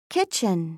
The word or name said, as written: kitchen